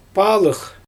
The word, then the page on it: paalyx: Xyzyl